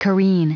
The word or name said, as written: careen